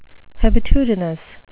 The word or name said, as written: hebetudinous